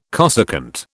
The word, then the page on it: cosecant: British English